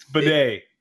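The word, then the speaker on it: Badet: And the pronunciation on this last name, is it Jeff Baudet or Baddett? Or... – Is that what I sound like when I try to say names out loud? – Yeah, pretty much. – yours was a lot more difficult than mine, but it can go. – It's Bidet.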